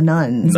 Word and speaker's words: nones: And so these people are colloquially called the nuns, nuns. – N-O-N-E-S.